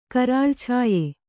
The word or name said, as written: ಕರಾಳಛಾಯೆ